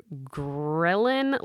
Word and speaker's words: ghrelin: And one I thought was really interesting, and I always mispronounce this word, but it lowers your ghrelin levels, which you said it right.